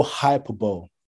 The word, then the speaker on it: hyperbole: No hyperbole or hyperbole, whatever it's pronounced, yeah? – Hyperbole, don't worry, I got you.